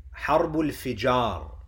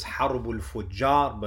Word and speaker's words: Harbul Fijar: Harbul Fijar. Some pronounce it as Harbul Fujar, but the more accurate pronunciation is Harbul Fijar, the Sacrilegious Wars.